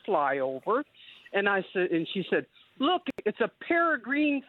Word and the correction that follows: peregrine: falcon." And she pronounced it like that. So we're little kids look up there saying, "How can she see two green birds all the way up there?" And we just about, we did not laugh because she'd have walked this one. But because she mispronounced it. It was years before I realized it was peregrine.